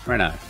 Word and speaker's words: Renault: Renault. – Oh, thank you. – Yeah. Or a Rolls Royce. – Rolls Royce. – It's confusing because it's spelt Renault, but said yeah. Renault.